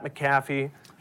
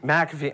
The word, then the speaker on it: McAfee: Rodgers was contrite in his Tuesday appearance on the Pat McAfee. – McAfee.